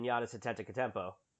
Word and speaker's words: Giannis Antetokounmpo: So when I'm talking about freakish athleticism, I go no further than Giannis Antetokounmpo as the guy I build my team around. – I like the name pronunciation. You mastered that.